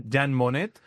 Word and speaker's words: Jean Monnet: O sea, ¿quién es este, que no sé si lo estoy pronunciando bien, Jan Monnet? – Jan Monnet.